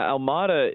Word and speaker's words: Almada: Speaking of that, and speaking of the roles that and guys want to play here, Thiago Alomada, I hope I said his name right. I'm working on my pronunciations. What role do you potentially see him playing when this deal is official? – Yeah, Almada is one of the most exciting young players out of Argentina.